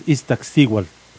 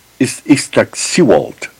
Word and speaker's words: Iztaccíhuatl: Uno es Popocatépetl y el otro es Iztaccíhuatl. – Iztaccíhuatl, okay.